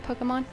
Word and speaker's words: Pokémon: They introduced some new Pokemon characters. Did I say it right? I did. – Yeah. – Not. Did I? – Yeah. – Okay. – Pokemon.